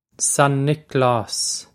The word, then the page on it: San Nioclás: Son Nick-laws